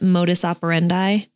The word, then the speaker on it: modus operandi: Modus operandi. Did I say that correctly? – Yeah, that's what you call it. Yeah, you did.